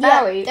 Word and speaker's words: Bowie: Which is interesting, because my first question about him was, how do you pronounce his surname? – David Bowie. – Bowie, that's right. And how do you remember that? – You remember it like Joey. – Remember that it rhymes with Joey.